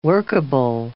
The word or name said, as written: workable